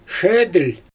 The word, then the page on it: ʃedɯlʲ: Xyzyl